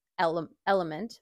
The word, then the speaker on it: LMNT: So again, that's spelled N-U-U-N. And then Element, which is spelled L-M-N-T.